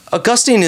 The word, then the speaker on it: Augustine: Augustine is a fine way to pronounce it.